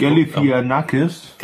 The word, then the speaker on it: Galifianakis: Der hat nämlich statt eine äh, Nuklearbombe auf die Krim zu werfen oder auf Moskau, hat er sich ähm, äh, zu Zach Galifianakis, Galifianakis, ich, ich, ich kann den Namen nicht aussprechen. Ich habe den ganzen Tag äh, die Videos nur geguckt. Galifianakis.